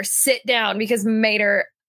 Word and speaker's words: Mater: It's pronounced like toe Mater, except without the toe.